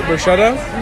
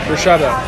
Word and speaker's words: bruschetta: Actually, that's a bruschetta? – No, you're saying it wrong. – Bruschetta. – Bruschetta. – What is that? – That's bruschetta, but you had to say it properly.